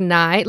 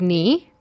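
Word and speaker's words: Ni: And I called them the Knights of Nye. Last week was like the week of mispronunciation, mother. And so I also called them the Knights of Nye, which is like super embarrassing, right? Because it's the Knights of Knee.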